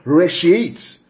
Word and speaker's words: reshiyth: Yeah, so in the beginning, that phrase in the beginning is the Hebrew word reshit. I'll spell it for you. Reshit. R-E-S-H-I-Y-T-H. Yeah, that's the word there, reshit. R-E-S-H-I-Y-T-H.